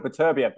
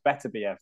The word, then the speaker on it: Beterbiev: I used to call it Beterbiev, but now it's Better BF, they're saying.